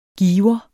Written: giver

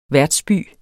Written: værtsby

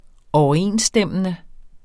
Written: overensstemmende

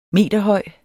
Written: meterhøj

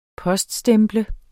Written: poststemple

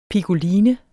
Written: piccoline

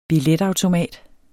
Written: BILLETAUTOMAT

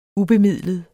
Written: ubemidlet

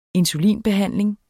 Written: insulinbehandling

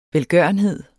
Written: velgørenhed

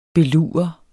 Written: belurer

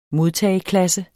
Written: modtageklasse

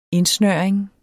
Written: indsnøring

